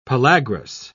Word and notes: pellagrous: British English

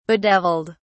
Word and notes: bedeviled: British English

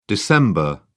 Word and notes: dec: British English

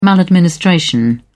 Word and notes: maladministration: British English

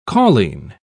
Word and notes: cauline: British English

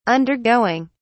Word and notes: undergoing: British English

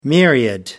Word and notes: myriad: American English

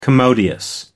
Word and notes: commodious: American English

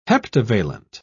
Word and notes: heptavalent: British English

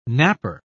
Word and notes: knapper: British English

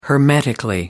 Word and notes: hermetically: British English

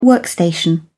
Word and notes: workstation: British English

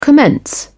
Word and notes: commence: British English

